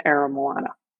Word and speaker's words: Aramoana: And around that time, he moved to the family's crib in Aramoana. Notice I'm using the hip language there. – Well done on pronunciation there.